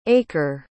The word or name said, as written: achor